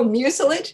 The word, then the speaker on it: mucilage: At school, she made her own books out of manila paper I don't know how to pronounce this word. – Oh, mucilage.